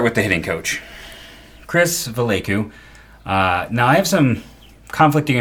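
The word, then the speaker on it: Valaika: Chris Vallecu. I believe I said it right. I went and I listened to a pronunciation guide just to have a better chance of getting this correct.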